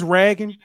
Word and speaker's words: Ragan: I know it's Ragan, but it's spelled like, you know how the Raging Cajun spell it with no G on the end. That's how it's spelled.